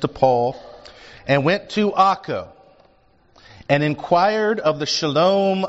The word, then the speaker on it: Akko: And this Ptolemais, that's Akko, A-K-K-O. That's its historic name. Its modern name is Akko, A-K-K-O. I'm going to call it Akko. Sometimes it's spelled in Bibles A-C-R-E, but it's Akko, okay?